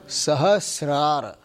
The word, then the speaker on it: Sahasrara: Sahasrara. – Not Trara. Rara. Sahasrara.